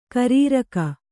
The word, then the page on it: ಕಾರೆರಕೆ: kārerake